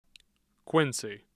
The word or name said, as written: Quincy